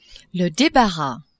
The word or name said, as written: debarras